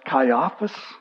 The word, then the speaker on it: Caiaphas: The high priest at the time was a man named Caiaphas, actually pronounced Caiaphas, but I'm not going to pronounce it that way because I'm just not used to that.